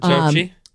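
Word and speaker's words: Doechii: And then, by the way, you go down to like the next 10, 11 through 20, Anxiety, the Dochi song, or how would you say her name? Is that it? – That's it, Dochi.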